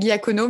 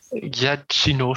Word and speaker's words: Giacchino: Moi, je dis Michael Giacono, mais ça ne doit pas se dire comme ça, je pense. – Bah, si, si, je pense que ça peut se dire comme ça. C'est Giacchino, je crois, mais.